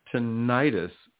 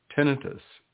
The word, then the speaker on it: tinnitus: A lot of people somehow for some reason pronounce it tinnitus, which is actually the incorrect pronunciation, tinnitus.